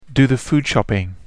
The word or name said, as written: do the food shopping